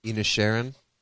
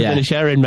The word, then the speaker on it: Inisherin: That Banshees of Inisherin. – Banshees of Inisherin, mate.